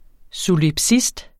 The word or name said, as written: solipsist